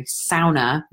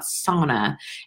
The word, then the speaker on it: sauna: That's how it's pronounced, by the way. It's the only Finnish word in the English dictionary, sauna, not sauna.